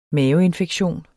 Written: maveinfektion

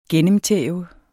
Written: gennemtæve

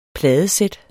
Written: pladesæt

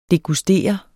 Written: DEGUSTERE

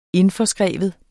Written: indforskrive

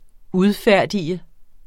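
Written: udfærdiget

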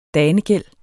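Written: danegæld

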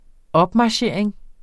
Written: opmarchering

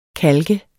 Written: KALKERNES

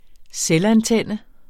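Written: selvantændt

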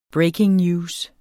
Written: breaking news

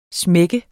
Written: smak

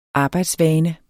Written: arbejdsvane

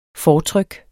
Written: fortryk

